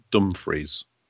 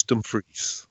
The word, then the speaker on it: Dumfries: Can I just point out that that's the correct pronunciation, not Dumfries, as, as people seem to say several times in the episode. It's Dumfries.